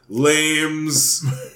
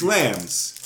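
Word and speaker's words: Lammes: And I remember on the show being like, I don't know how to pronounce it. Lames. Lames. I don't know. It's L-A-M-M-E-S. – Yeah. – It's Lambs. You know how I know it's Lambs? Look at the logo. – Oh, it's a lamb.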